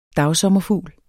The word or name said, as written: dagsommerfugl